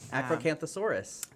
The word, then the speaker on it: Acrocanthosaurus: Acrocanthosaurus.